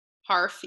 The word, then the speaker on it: Harf: Harf, yes.